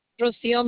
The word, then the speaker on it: Rocio: Rocio Melendez. – Can you spell it for me? – First name is R-O-C-I-O, and the last name is Melendez, M-E-L-E-N-D-E-Z.